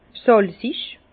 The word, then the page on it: sàlzig: Bas-Rhin; Herrlisheim